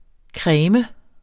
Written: cremes

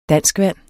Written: danskvand

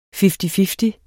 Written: fifty-fifty

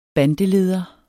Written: bandeleder

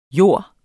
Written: JORDS

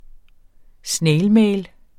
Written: snailmail

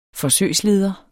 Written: forsøgsleder

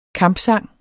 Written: kampsang

